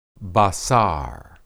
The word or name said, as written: basar